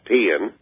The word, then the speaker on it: paean: P-A-E-A-N. It is pronounced peeing.